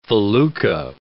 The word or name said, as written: felucca